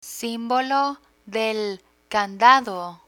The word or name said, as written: simbolo de candado